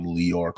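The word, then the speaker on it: Lyor: Chris Lighty is one of the executives that was mentored by Lear Cohen, which I call him Liar Cohen. I pronounce it as liar because he's a liar. But they call him Lior Cohen.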